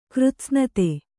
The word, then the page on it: ಕೃತ್ಸ್ನತೆ: Kannada